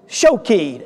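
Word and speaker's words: shokeid (שֹׁקֵד): The word for almond branch, we'll say it in the Hebrew, but it is shakeid, spelled S-H-A-K-E-I-D if we were to spell it with English letters. And the Hebrew word for watches is similar to that, or sees. It is, maybe even to be on guard, it is shokeid, S-H-O instead of S-H-A-K-E-I-D.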